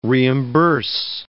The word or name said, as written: reimburse